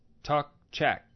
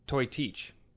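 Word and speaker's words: Toiteach: It's a Gaelic word, so it's spelled weird, but it's Tok Chak, which looks like it should be Toy Teach.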